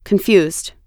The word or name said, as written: confused